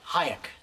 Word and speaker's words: Hayek: How do you pronounce her last name? – Hayek. Selma Hayek.